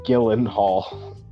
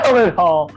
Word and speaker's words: Gyllenhaal: Gyllenhaal. – Jake Gyllenhaal. – Gyllenhaal. Okay. – Yeah. It's spelled G-Y L-L-E-N-H-A-A-L.